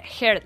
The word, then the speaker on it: Heard: El actor se casa con Amber Heard. Lo he dicho bien, ¿no? – Sí, Heard.